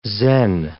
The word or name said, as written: Zen